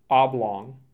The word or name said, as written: Oblong